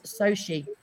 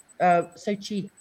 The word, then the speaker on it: Sochi: Oh, and Bonita says it's pronounced Sochi. Sochi. I was completely wrong.